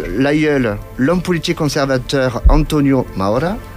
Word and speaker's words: Maura: Pour le 15, nous souhaiterons l'anniversaire de Carmen Mora. Alors, je ne dois pas bien le prononcer, puisque c'est une actrice espagnole. Carmen Maura, qui va fêter donc ses 72 ans.